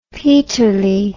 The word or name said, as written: peterlee